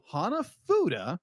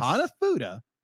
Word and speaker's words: Hanafuda: So Hanafuda. I'm saying that right? – Yes. – Hanafuda.